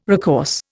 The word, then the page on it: recourse: Indian